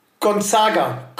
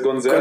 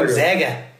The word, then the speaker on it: Gonzaga: 2009 hat sich entschieden, Gonzaga College. – Bitte, bitte sprich es richtig aus. Das heißt Gonzaga. – Gonzaga.